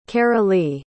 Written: carolee

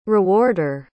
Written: rewarder

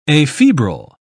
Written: afebrile

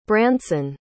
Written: branson

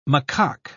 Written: macaque

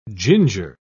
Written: ginger

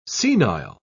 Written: senile

